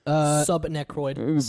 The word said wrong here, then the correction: subarachnoid: Arachnoid? I mean, that's how it's SUBARACHNOID.